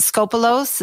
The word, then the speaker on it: Skopelos: Is it is it Skopelos? Is that how you say it? I don't, yes, I don't know how yes.